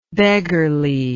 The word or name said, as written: beggarliness